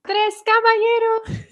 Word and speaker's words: Tres Caballeros: how do you say it? – Tres Caballeros. – That's right.